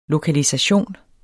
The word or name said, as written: LOKALISATIONEN